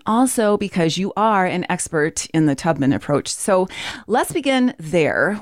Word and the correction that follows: Taubman: with you explaining what the Tubman, I, I should know how to pronounce that. How do I pronounce that? Taubman.